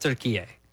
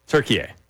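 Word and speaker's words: Türkiye: They pronounce it turkey A. – Turkey A. – Okay, like, so over we'll there, it's pronounced turkey A.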